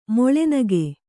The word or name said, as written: ಮೊಳೆನಗೆ